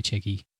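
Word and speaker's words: Tcheky: The spelling of his name is a sort of a way for French people to pronounce it phonetically, because it's actually like, it's spelled like D-J-A-K-Y in Turkish or whatever. But so it's in French, it's T-C-H-E-K-Y, Chicky.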